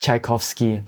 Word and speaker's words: Tchaikovsky: Tchaikovsky.